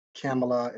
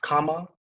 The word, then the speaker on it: Kamala: And also, people, it's not Kamala. It's not, it's Kamala. That's how you pronounce it.